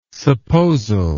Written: supposal

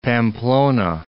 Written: Pamplona

